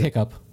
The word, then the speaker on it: hiccough: C-O-U-G-H. – Yeah. They say it hiccup, but they spell it hiccough.